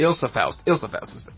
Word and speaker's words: Ilsa Faust: Rebecca Ferguson still kicks ass as Isla Faust. – Mm-hmm. – Ilsa Faust is how you pronounce that one.